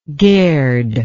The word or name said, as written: gerd